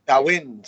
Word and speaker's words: Samhain: Uh, as, as, they continue to grow, as NWA continues to grow the Sawan. Sawan. Sawin. – Sawin. Say it right. Sawin.